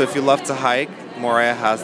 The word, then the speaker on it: Moorea: So tell me about Moorea, is how you say it? – Moorea, yes. So Moorea has, it's known for its lush green mountain peaks and some their beautiful bays.